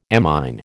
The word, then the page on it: amine: American English